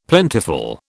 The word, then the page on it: plentiful: British English